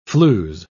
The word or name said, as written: flews